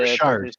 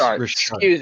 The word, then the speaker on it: Richard: It's Maurice Richard. – Excuse me. I'm sorry. I'm not the biggest hockey guy. So I was reading it as I was saying it, and it's spelled like Richard. So Richard.